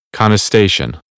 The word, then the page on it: contestation: American English; ɑː